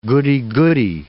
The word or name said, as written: goody-goody